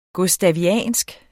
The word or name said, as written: gustaviansk